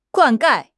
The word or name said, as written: 灌溉